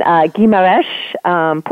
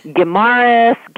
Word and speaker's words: Guimarães: I'm in Guimarães, Portugal. So, okay, the Portugal part is just regular. So, yeah. And I've been calling it Guimarães, you know.